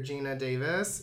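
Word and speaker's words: Geena Davis: You know, we see, like, an older Gina Davis.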